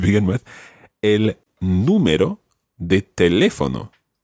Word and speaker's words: teléfono: So if there was no accent on teléfono, it would be teléfono, but that's wrong. So it's teléfono.